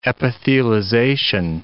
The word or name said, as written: epithelization